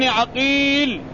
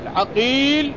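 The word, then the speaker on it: عَقيل: مثال ذلك: فمحمد ابن عقيل بفتح العين، ومحمد ابن عقيل بضمها، أسماء الرواة اتفقت محمد ومحمد، وأسماء الآباء اتفقت في الخط واختلفت في النطق. الأول عقيل بفتح العين، والثاني عقيل بضمها.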